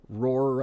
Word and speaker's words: ROIR: The New York underground label Roar, I think I'm pronouncing it right, R-O-I-R,